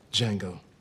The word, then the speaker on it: Django: Django. – Can you spell it? – D-J-A-N-G-O. The D is silent.